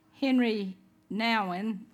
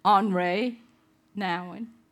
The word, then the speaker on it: Henri Nouwen: Henry Nouwen, if you're at Candler, they would teach you to pronounce it Henri Nouwen, wrote a book called The Wounded Healer.